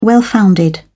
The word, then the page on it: well-founded: British English